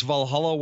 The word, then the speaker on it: Valhalla: Is that Valhalla? Did I say that correctly? – Yes, Valhalla Wealth Financial.